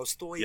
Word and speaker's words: Stojka: And he corrected me before. I made sure to ask. I was like, am I saying this right? He's like, no, the J is Y. I was like, oh, Stojka. – Yep.